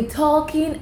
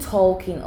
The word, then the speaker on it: talking: Wait, is it talking or talking? – All of the above. – No, because people used to tell me that the L is silent in talk.